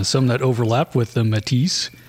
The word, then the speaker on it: Métis: Métis? Like marshmallow Métis? – It's spelled like Métis, but sometimes the S is silent depending on who's doing it.